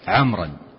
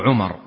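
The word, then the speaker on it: عمرو: والحكمة في كتابتها التمييز بين عمر وعمر، فهذه الواو تميز بين من اسمه عمر ومن اسمه عمر، ولولا هذه الواو لم يحصل التمييز، فالواو إنما تكتب للتمييز بين عمر وعمر بين عمر وعمر، فهي واو تكتب ولا تنطق.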